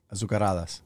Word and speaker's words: azucaradas: The Z is silent, right? It's no. – Azucaradas. – It's a Z. – How do you say it? – Azucaradas.